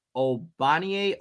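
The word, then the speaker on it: Ogbonnia: But and I heard his first name pronounced two ways, but I'm going with the way that I wrote down here. Obanye Okoronkwo would be the way. No G, even though he has a G in his name.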